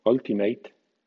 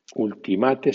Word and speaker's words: ultimate: o bien W-I-N-D, puede ser wind o wind, o bien separate, puede ser separate o separate. La pronunciación que has oído en algún anuncio de la televisión como ultimate no existe. Ultimate se dice ultimate, solamente.